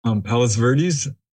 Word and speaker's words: Palos Verdes: Am I saying that right? – Palos Verdes? Yeah.